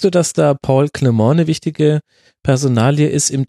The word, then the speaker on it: Clement: Also was man so gehört hat, war, ich hätte ihn jetzt ja Paul Clement ausgesprochen als Engländer, aber ja. – Aber ist auch richtig so.